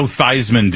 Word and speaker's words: Theismann: He should do what Joe Theismann did when he said, oh, yeah, Theismann rhymes with Heisman, and then he won the Heisman Trophy that year, even though he never pronounced his name that way.